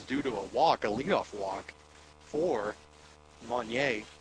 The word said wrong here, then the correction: Munier: I know I butchered that, but now he's back up, Munier, and now he's back up to bat.